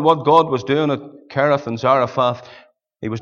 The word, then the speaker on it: Cherith: Mr. Douglas taught us to say Cherith. The CHs in the Bible, you pronounce them as a K. You don't say the chemist shop, you say the chemist. There's a harder sound, the brook Cherith.